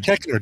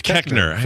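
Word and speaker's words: Koechner: David Kochner. Or Kochner, however you say his name. – Kechner. David Kechner.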